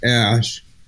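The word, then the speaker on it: Æ: He explained it's just X, the letter X, and then AE pronounced Ash, and then A12 is my contribution.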